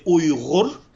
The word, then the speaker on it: Uyghur: So in my language, you would say Uyghur. Uyghur. But in the Western English, they normally Uyghur. Say Uyghur.